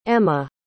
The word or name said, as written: emma